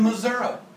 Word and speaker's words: Missouri: I got weird folk in Missouri. I know they're weird because it's spelled with an I on the end, but pronounced with an A.